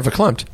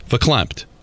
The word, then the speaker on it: verklempt: I'm going to predict that Michael may get, I think I'm saying this right, verklempt? – Yeah, verklempt. – Verklempt.